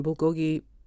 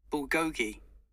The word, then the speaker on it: bulgogi: It doesn't say it here, but I've also found bulgogi is a bit sweet.